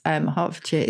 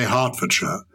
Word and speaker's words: Hertfordshire: Hertfordshire is beautiful. And you say Hertfordshire. Other people would say Hertfordshire. I don't quite know why. The T is silent.